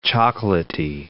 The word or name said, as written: chocolaty